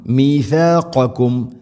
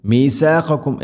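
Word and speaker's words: ميثاقكم: മീഥാഖക്കും മീസാഖക്കും എന്നല്ല മീ സീനാവരുത്.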